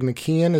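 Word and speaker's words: McKeown: I just finished it like 4 days ago, called Essentialism by, I think it's Greg McKeon is the way you pronounce it, even though it's spelled kind of funky, Essentialism.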